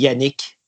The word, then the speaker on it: Yanick: My French Canadian friend will call me Yanick.